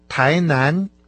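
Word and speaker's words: Тайнань (台南): Да, совершенно верно, конечно же, о городе Тайнань. Оба компонента этого названия произносятся со вторым тоном.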